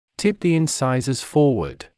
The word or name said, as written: tip the incisors forward